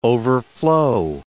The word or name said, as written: overflow